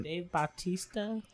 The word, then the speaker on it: Bautista: Dave Bautista.